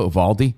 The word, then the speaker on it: Uvalde: Is that how you say it, though? Uvalde? – Yeah.